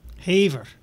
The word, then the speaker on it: Havre: Haver. – Haver? He said, it's pronounced like the old song. I don't want her, you can have her.